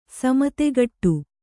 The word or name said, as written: ಸಮುತ್ಕಟ